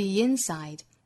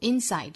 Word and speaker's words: inside: 如此一来连读就是 on the inside, the inside. 或者我们可以重读 inside 这个词，我们知道它本身重音是在后面的，读成 inside, 但是在有对比强调的时候呢，它会把重音转移在前面这个音节，读成 inside, on the inside of a person. 如果重读 inside 这个词呢，就可以在前面形成短暂停顿了，那读起来的效果是 that occur on the inside of a person, occur on the inside of a person.